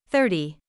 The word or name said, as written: thirty